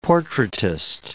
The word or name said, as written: portraitist